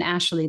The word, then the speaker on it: Ashley: It's so funny you mentioned Ashley. That's my middle name. And I spell it L-E-Y, which is the typical male spelling.